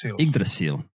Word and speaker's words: Yggdrasil: I want to say Yggdrasil? The Yggdrasil secret? – Yggdrasil. – Okay. Can you tell me what this was? I didn't get a chance to watch this. – Okay. So the Yggdrasil is what is known as the world tree in the mythology written in Old Norse texts.